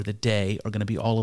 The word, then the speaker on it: Te: and they're going to use the word day um, which is again spelled with a T again T E H, but it's um, it's it's going to be the the other principle.